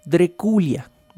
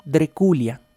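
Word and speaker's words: Drăculea: Drácula, que realmente en rumano se pronuncia Dreculia, Dreculia.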